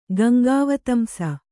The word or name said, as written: ಗಂಗಾವತಂಸ